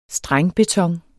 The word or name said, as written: strengbeton